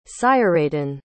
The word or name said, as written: seireadan